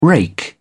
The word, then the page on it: rake: British English